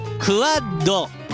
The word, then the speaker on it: QUAD: QUAD と書いてクワッド。